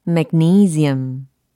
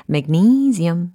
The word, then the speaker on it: magnesium: magnesium, magnesium. 무엇일까요? magnesium. 아하! 이 단어는 바로 마그네슘에 해당하는 영어 발음이었습니다. 와, 마그네슘일 줄 알았는데 magnesium 이렇게 발음이 되는군요. 그렇죠? magnesium, magnesium, magnesium 이라고 하시면 되겠습니다.